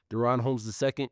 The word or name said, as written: Daron Holmes II